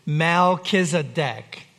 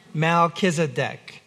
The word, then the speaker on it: Melchizedek: Melchizedek. Melchizedek.